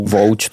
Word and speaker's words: Vogue: A tam se přiznám, že jsem teda slabý v kramflecích, protože Vogue já jakoby neumím. Ale... – Ani vyslovit. – Jo, pravda, ano, ono se to točí vzle Vouč tomu říkáme.